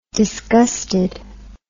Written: disgusted

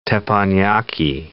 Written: teppanyaki

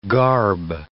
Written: garb

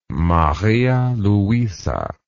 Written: Maria Luisa

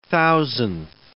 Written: thousandth

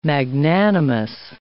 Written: magnanimous

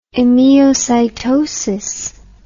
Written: emiocytotic

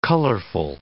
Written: colorful

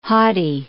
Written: haughty

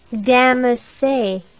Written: damasse

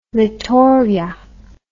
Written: Littoria

